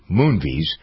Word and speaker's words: Moonves: And I wonder if there's a little, the Redstones are not happy with Moonves. So how much of that is behind this as well? How much behind the scenes stuff is going on to discredit Moonves, who wants to get rid of the Redstones, and the Redstones don't want to go.